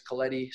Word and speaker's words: Coletti: So Anthony, A-N-T-H-O-N-Y. Last name is Coletti, C-O-L-E-T-T-I, and a little underscore at the end.